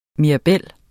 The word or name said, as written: mirabel